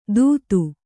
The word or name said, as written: ತುತ್ತು